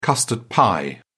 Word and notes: custard pie: British English

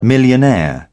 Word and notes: millionaire: British English

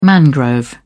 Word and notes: mangrove: British English

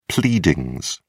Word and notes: pleadings: British English

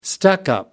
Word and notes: stuck up: American English

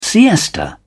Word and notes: siesta: British English

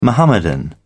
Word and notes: mohammedan: British English

